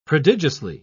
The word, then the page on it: prodigiously: British English